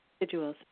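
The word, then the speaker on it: sigil: And we did sigils. Is that how you say it? – Sigils.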